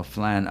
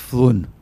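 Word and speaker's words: Flåm: It's spelled F L A N, like Flan. – Mm-hmm. – You know, like. – But it's probably not pronounced that way. – No, it's pronounced Flun.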